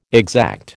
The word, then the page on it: exact: American English